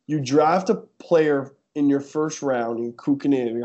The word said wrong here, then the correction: Kotkaniemi: I can't pronounce it, Kukkonen or whatever. – Kotkaniemi, yeah.